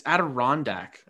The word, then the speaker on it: Adirondack: Sorry, how did you say that? Adriatic? It's Adirondack.